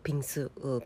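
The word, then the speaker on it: ピ ン ス (빙수): 私はフルーツがたっぷり乗った、今回食べたようなアップルマンゴーのピンス、ピンスっていうのは氷に水と書いて韓国語でピンスと読むんですね。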